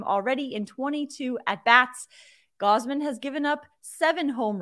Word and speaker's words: Gausman: Kevin Ga- Gossman, Goss- Gausman. There's three ten ways to pronounce this name. Kevin Gausman.